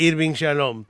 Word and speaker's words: Irving Yalom: Escúchame, El Enigma Spinoza de Irving Yalom, de Editorial MC. – De Irving Yalom.